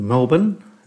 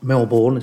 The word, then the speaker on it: Melbourne: So from Durban, he made his way to Melbourne, I think that's how the Australians pronounce it, not Melbourne, as I kept calling it.